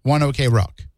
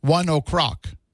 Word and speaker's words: One OK Rock: I've seen a lot of reviews of them on YouTube, people doing like those listening reaction videos, and they refer to them as One OK Rock. But when you go to the band's website, it says that it's pronounced 1 o'clock. And it says, it's like you're saying 1 o'clock with a Japanese accent.